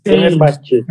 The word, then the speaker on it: Fenerbahçe: Ele fez Brasileiros do Fenerbahçe, que a pronúncia é essa, né? – Fenerbahçe.